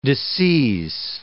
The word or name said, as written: disseise